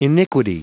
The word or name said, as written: iniquity